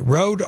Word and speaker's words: Rohde: I am not familiar with Dr. Robert, is it Rohde, R-O-H-D-E? – That's the way I pronounce it.